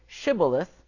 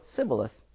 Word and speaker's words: Shibboleth: And so when they were given this test to say Shibboleth, they couldn't pronounce the SH. They just would go Sibboleth.